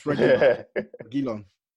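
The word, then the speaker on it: Reguilón: And it looks like we're getting Bale and Reguilon. Am I saying that right? – It's Reguilon.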